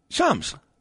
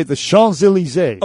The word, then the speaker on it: Champs-Élysées: The P is silent. – Champs. – No. The M. They actually say the Champs-Élysées. – Okay. – Like an N.